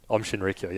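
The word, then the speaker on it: Aum Shinrikyo: Anyway, so in the investigations that sort of spread around that, they realized that Om Shinrikyo, which was the cult. – Is that how you say it? – Om Shinrikyo, yeah.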